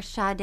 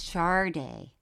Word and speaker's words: Sade: You mean people would just come up to you and go, hey, don't ever call her Sade. It's Sade.